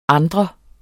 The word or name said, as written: anden